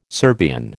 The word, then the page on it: Serbian: American English